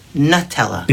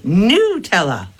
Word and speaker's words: Nutella: The U is sharp. Nutella. Well, that makes sense because there's nut in it. Got it. But Americans pronounce it like ooh, like an ooh. So we say Nutella. We make it a ooh, not a Huh? – Huh?